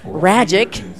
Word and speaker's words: Ragic: On the Palm Beach side, well, Max Ragic, rhymes with magic, the righty, went five innings, couple of hits, a run.